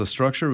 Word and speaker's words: Struxure: It's called Structure. It's spelled how? – S-T-R-U-X-U-R-E. – X-U-R-E. And this is a product of Signature Deck. – Yep. – Tell me about this company. – Okay, so Structure was developed in 2011.